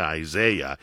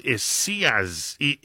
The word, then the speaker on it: Isaias: It is not Isaiah. It is Isaias. Isaias. Isaias.